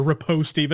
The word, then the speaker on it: riposte: Riposte or riposte, but he like, you know, said the E out, this E sound at the end of it. And no, it's riposte, gentlemen. It is very distinctly riposte, at least, you know, in English pronunciation.